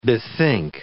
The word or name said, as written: bethink